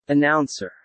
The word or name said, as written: announcer